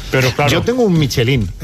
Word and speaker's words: Michelin: Oye, Michelin, ¿cómo se pronuncia definitivamente? – Michelin. A ver, aquí en la Península Ibérica hacemos Michelin.